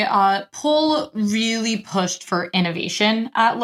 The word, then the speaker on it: Poul: Paul Paul would be the first name, like literally how it's spelled.